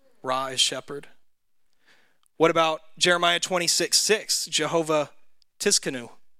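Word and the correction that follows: Tsidkenu: Sidkanu, is that how you say it? Sidkanu. Okay, so the T is silent. It's like tsunami.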